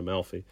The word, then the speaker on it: Amalfi: And further down the coast, you've got another town, Positano, and you've got another town, Amalfi. But we... – Dr. Malfi. – Yeah. It's pronounced more like a Malfi cow. That's how it's pronounced.